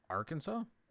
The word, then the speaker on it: Arkansas: Arkansas? – Thank you.